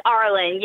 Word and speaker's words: Arlen: It's Arlen.